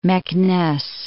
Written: Meknes

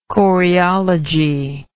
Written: choreology

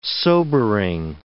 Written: sobering

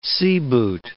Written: seaboot

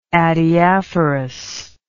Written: adiaphorous

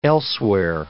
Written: elsewhere

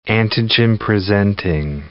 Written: antigen-presenting cell

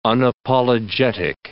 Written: unapologetic

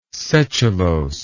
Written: setulose

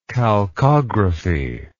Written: chalcographical

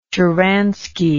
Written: shcharansky

